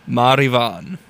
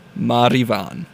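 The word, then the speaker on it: Máire Bhán: And then before that was Ye Vagabonds with, get ready for this one, Marivan. Yep, I said it just like the Gaelic pronunciation would entail. Marivan.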